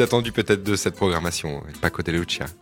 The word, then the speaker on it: Paco de Lucía: Paco de Lucía. – Voilà, comme ma région, Andalucía.